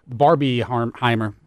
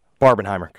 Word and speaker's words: Barbenheimer: The new Barbieheimer. – Barbenheimer.